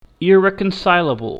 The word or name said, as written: irreconcilable